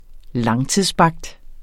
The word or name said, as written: langtidsbagt